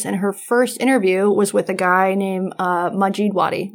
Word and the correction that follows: Majdi Wadi: Majid Wadi? How do you pronounce it? – Majdi Wadi. Yes.